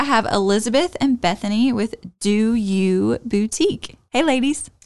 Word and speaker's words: Dew You: I mean, Do You, always. – Thank you. – And it's spelled D-E-W-Y-O-U.